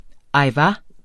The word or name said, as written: ajvar